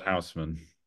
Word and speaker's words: Haussmann: Boulevard Haussmann.